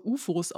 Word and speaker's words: OOFOS: Ähm, ich glaube, es wird auch eher so UFOs ausgesprochen. Ja, das sind einfach nur, äh, die sehen aus wie, ähm, wie Flipflops. Also die Form des Schuhs ist wie ein Flipflop, aber, also geschrieben wird das O-O-F-O-S so ist der Name dieser Brand, gibt es wahrscheinlich auch wieder von günstigeren, Her also günstigere Modelle, andere Hersteller.